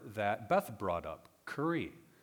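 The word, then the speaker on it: kuri (קרי): So if you look at verse 21, and it reads, yes, if you go against, that go against is that Hebrew word, kuri. It's spelled kaf, resh, yod.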